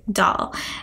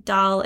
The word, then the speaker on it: Doll: Doll. And yes, Doll is spelled D O L L, and that is my real last name.